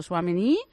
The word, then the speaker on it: Tchouaméni: y le he dicho Tchouaméni y se ha dado la vuelta, ha sonreído, me ha guiñado un ojo y nada, seguido.